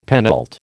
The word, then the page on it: penult: American English